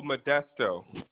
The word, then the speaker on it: Modesto: Is it Modesto? Modesto? – Oh, Modesto.